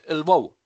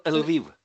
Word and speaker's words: Lviv: We pronounce it Lvov or Lviv.